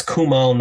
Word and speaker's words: Kumail: Kumani is so amazing. Oh, how do you say his name? Kumani? – It's Kumail Nanjiani.